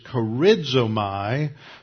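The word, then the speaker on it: charizomai (χαρίζομαι): It's the synonym used in several passages, charizomai, charizomai, let me spell that for you again, charizomai, charizomai, charizomai from charis, meaning grace.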